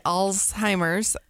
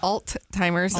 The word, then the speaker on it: Alzheimer's: So some people say Alzheimer's, and some people say it's Alt-timers, and some people say it's Old-timers, but it's ALZ.